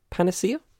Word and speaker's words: panacea: The report is no panacea? Is that how you say it? Panacea? Yeah, panacea.